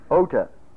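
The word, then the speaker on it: Ota (ὦτα): Now, this is the plural of ears, and it doesn't look like O-U-S up above. It's a long O-T-A. See? Ota. Ota.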